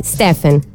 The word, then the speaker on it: Stephen: A proposito di immagini non terribili, un altro grande scrittore più recente, quindi Stephen King, va bene la pronuncia? Stephen. Sì, da Stefano King.